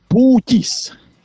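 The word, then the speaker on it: Pūķis: Can you pronounce after me? It's actually Pukis. – Yeah, that's what I said. Pukis. – Pukis. No, Pukis. Not Pukis. Pukis.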